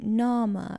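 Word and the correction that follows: Niamh: It's like N-I-A-M-H. – Oh, it's Neve.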